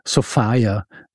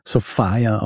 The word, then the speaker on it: Sophia: Ich werde sie bei ihrem Vornamen nennen, also Sofia, geschrieben Sophia, aber entsprechend der damaligen Mode auch wird sie tatsächlich Sofia ausgesprochen.